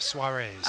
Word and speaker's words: soirees: A-N-D. No, I'm joking. S-O-I-R-E-E-S. – Soirees. – Okay, so there's a series of soirees called S-O-S. – Save our soiree? – No, it's just S-O-S, series of soirees.